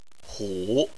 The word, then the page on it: hu: 3